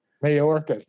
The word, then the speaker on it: Mayorkas: Mayorkas.